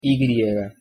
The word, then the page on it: y: Spanish